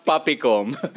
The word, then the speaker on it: PAPCOM: Papicom na ba? Papicom. – Papicom. – Pronunciation nila Papicom daw yun eh.